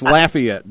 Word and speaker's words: Lafayette: I'm told that's pronounced Lafayette down there, not Lafayette.